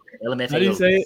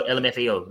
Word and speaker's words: LMFAO: LMFAO. There you go. LMFAO.